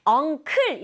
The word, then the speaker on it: uncle: 오, 이는 소리 안 나요. 그래서 uncle인데 자연스럽게 발음하면 uncle보다는 uncle 이렇게 끄 소리 나는 게 좀 더 자연스럽다.